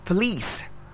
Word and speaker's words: police: The next word is the word P-O-L-I-C-E, and that's police. Police. The O there has a shoe sound. It's not po. It's not the O there. It's police, the policeman, the policewoman. So it's police, that's how it's pronounced. Police, police, police. All right, the next was the word C-A-M-O-U-F-L-A-G-E.